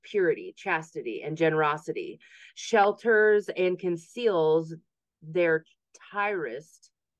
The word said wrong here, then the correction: tryst: What's that word? – Tyrist. – T-R-Y-S-T. – T-R-Y-S-T. A private romantic rendezvous between lovers. – Wow. Okay. – And it's pronounced tryst.